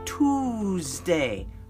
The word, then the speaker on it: Tuesday: Capital T-U-E-S-D-A-Y. Now, Tuesday. There's no Z in there, but we pronounce the S like it's a Z. So it's Tuesday. Tuesday comes after Monday.